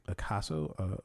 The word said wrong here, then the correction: Ahsoka: No, it's Ahsoka. – Ahsoka.